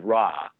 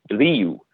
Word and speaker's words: Ra: One of Stuart's favorite stories about the original movie is that so the god Ra is a very anglicized pronunciation of what they originally would have called the god. In Coptic, I believe it's pronounced Rea, so R-E-E-U-H, if you want to like think about it phonetically, like Rea. But in ancient Egyptian, that becomes an oo sound, so it's Riu.